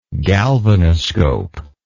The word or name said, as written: galvanoscopy